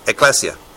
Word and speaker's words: ekklesia: The kahal of Israel is the congregation of Israel, and it's translated oftentimes through the Septuagint into the Hebrew word synagogue, but also ekklesia. Did I say that right? Or ekklesia, or whatever it is. My prisoner down there who is Spanish says it's ekklesia.